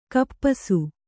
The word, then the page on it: ಖಪ್ಪಸು: khappasu; Kannada